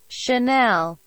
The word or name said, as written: Chanel